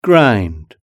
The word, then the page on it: grind: British English